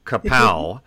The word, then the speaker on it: cacao: I didn't know how to say it, and so I sort of tried different things out, and you taught me it's cacao. Like the Batman cartoon, but that's not the same as cocoa, the stuff that goes in your hot chocolate drink.